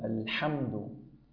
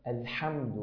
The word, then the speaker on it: الحمد: الحمد، الحمد، يعني سأكثر لك من صوتها حتى يصل إليك بشكل أدق. الحمد، الحمد، بهذا الصوت يا أخي الحبيب.